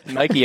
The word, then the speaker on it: Nike: Let's put it like this. Nike it is.